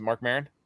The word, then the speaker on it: Marc Maron: Is it Marc Maron? Did I get the name right? Yes. Yeah.